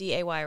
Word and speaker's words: Maundy: The next special day during Lent that we celebrate is Maundy Thursday. – Yeah. – Do you say Maundy? – Uh-huh. – Do you know what that means? – Or Monday, but it's Maundy, right? – Yes.